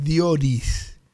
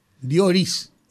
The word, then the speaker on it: Dioris: Sí, está eh, Pero, no es Dionis, es Dioris. Dioris. Entonces, como nadie lo entendía y todo el mundo lo pronunciaba diferente, yo lo corté y me quedé con Dios y ahora todo el mundo lo dice bien.